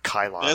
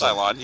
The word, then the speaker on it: Cylon: I hope I'm saying that right. It's not Kylon. – It's Cylon, yeah.